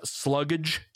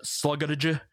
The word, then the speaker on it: Slugdge: So for the album that Mitch gave me, there is a band out of the UK, and correct me if I pronounce this wrong, but I believe it is Sluggage.